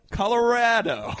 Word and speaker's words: Colorado: It's somewhere in the middle. Colorado. – Colorado. Yeah, you say Colorado. – Yeah. – I say Colorado.